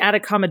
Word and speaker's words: Atacama: He first encountered you in the Atacama Desert. Am I saying that right? – You are actually saying the Atacama Desert right, although we officially met in a small town called Uyuni, Bolivia, which is just on the eastern side of the desert.